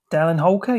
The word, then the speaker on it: Dallin Holker: I'm gonna go to Dallin Holker. Is that the pronunciation we're going on this one? – Dallin Holker, yeah. – Okay, Dallin Holker, yep.